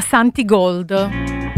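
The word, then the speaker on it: Santigold: Allora, la cantante di prima, grazie a te Vale, si chiama Santi Gold, proprio come lo pronuncio.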